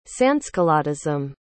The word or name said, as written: sansculottism